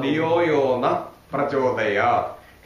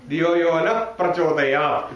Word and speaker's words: धियो यो नः प्रचोदयात्: धियो यो न प्रचोदयात् केचन जनाः कथम् उच्चारणं कुर्वन्ति धियो यो न प्रचोदयात्